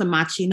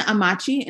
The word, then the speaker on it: Amakye: And my last name is pronounced Amakye. Nobody ever says it that way, but I'm sorry. – No, you're fine. At Sethlina Amakye, and that's Sethlina Amakye.